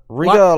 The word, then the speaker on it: Riga: He's from, I want to say this correctly, Riga? – Riga, Latvia.